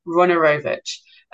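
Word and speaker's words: Wojnarowicz: So last week I couldn't pronounce it, and this week I looked it up on YouTube, Runorovich.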